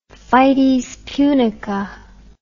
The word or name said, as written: fides punica